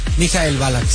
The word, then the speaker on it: Michael Ballack: ¿Cómo se dice bien? ¿Michael Ballack? – Michael Ballack, sí.